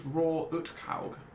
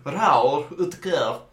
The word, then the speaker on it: Roar Uthaug: Who's Roar Uthaug? – You might not be pronouncing it right. – Roar Uthaug. – Oh, yeah, that's better.